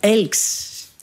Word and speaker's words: Elche: Vous avez prononcé pour Elche, vous avez dit Elx. – Oui, c'est ça, Elche. – Dites, mais vous parlez le valencien.